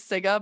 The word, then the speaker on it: Sygga: Her name is Siga, but it's spelled S-Y-G-G-A.